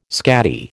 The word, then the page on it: scatty: American English